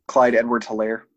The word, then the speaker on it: Clyde Edwards-Helaire: Clyde Edwards-Helaire.